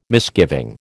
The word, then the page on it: misgiving: American English